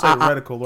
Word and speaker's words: reticle: Right in the rectal. did you just mispronounce the word? – Yeah, reticle, right? – Yeah, I meant to say reticle, and I was just reading.